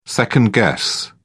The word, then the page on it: second-guess: British English